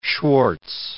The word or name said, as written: Schwartz